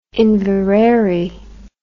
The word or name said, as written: Inverary